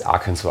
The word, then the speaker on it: Arkansas: Arkansas schreibt man es, man spricht es Arkansas aus, wusste ich auch noch nicht, habe ich auch gelernt.